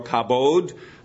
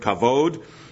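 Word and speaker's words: kavod (כבוד): The one word, the one Hebrew word, is kavod or kabod. Depends how you pronounce that particular, whether there's a dot in that particular word or not. It's the word kavod.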